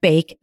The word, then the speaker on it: bake: K-a-t. So let's take the word bake. In the word bake, there are also three sounds. B-a-k. So you don't hear the silent E at the end.